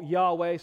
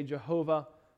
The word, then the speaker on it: Yahweh: Even G-O-D, they do not write out in English. It's G-D. And so this word that we call Yahweh, some say Jehovah, but there's no J sound in Hebrew. That's why we say Yahweh. It's a more likely pronunciation.